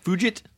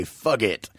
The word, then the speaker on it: Fugit: I like – it's Patrick Fugit. – I like to say fug it. – Fug it. – But I don't know. No, it's Fugit. – Fugit. – Yeah. – It's hard G.